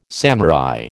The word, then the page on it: samurai: American English